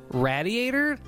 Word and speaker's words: radiator: So, a very select few people say radiator, like it rhymes with gladiator. – Nobody does. – you're right. Almost no one says it like that. And then I found out that's sort of like a New York Jewish pronunciation.